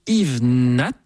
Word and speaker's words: Yves Nat: Et on va ouvrir cette nouvelle saison par un hommage à un très grand pianiste français du siècle dernier, qui s'appelait Yves Nat. On prononce bien noté, absolument, voilà.